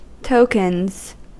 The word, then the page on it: tokens: American English